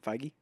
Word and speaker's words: Feige: Feige.